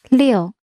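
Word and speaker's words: 六: Liu. – Yes, Liu. So now we can replace the L sound with J, J, J. So it's Jiu. – Jiu. Yeah, Liu.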